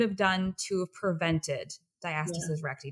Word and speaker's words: diastasis recti: Let's talk a bit about, is it diastasis recti? So am I pronouncing that correctly? – Yeah, diastasis recti.